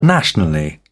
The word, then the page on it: nationally: British English